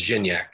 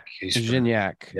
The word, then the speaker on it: Gignac: It's Gignac. – Gignac. Yeah. I did know that, but it always slips the mind. So Gignac, he also got hurt last match in the 77th minute.